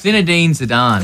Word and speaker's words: Zinedine Zidane: And I knew that had googled it because how do you pronounce his name? – It's Zinedine Zidane.